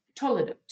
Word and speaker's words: toledot: It's the word toledot. T-O-L-E-D-O-T. Or sound it out better for yourself. It doesn't matter. We'll learn the Hebrew later. But the word is pronounced toledot.